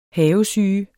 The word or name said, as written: havesyge